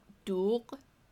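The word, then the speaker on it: doogh (دوغ): My favorite Persian drink is called doogh. – Mm-hmm. – It's spelled D-O-O-G-H. And it's that g sound.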